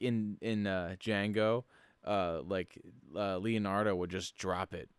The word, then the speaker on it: Django: Django. – It's so good. – The D is silent.